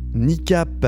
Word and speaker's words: Kneecap: Il est le coup de cœur de la semaine, qui, cette semaine, ne pouvait être autre que le nouvel album de Niecap. Alors, ça s'écrit K-N-E-E-C-A-P.